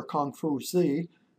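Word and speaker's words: Kong Fu Zi (孔夫子): Confucius, or Kong Fu Zi, which is how you say his name in Mandarin Chinese, lived in the 6th century BC, wanting to create a philosophy to generate social stability.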